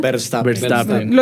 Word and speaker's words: Verstappen: Yo he escuchado por ahí un tal Verstappen, ¿cómo es que se llama? – Verstappen.